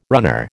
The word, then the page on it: runner: American English